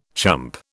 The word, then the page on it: chump: British English